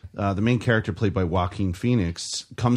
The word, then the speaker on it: Joaquin: It's Joaquin. – It's Phoenix. Joaquin. – It's Joaquin. – That's how he pronounces it.